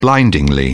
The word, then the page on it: blindingly: British English